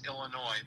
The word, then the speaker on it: Illinois: Well, I pronounce it without saying the S, Illinois. But a lot of people will come in and pronounce it Illinois.